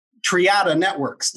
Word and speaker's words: Triada Networks: And you are the president and CTO of Triada Networks.